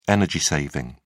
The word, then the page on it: energy-saving: British English